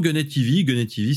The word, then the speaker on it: Gunhead TV: Ou faut le prononcer à la française peut-être. – Non, Gunhead TV c'est très très bien.